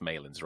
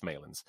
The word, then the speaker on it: Malins: It's pronounced Malins, right? Or is it? Yeah, it's Malins.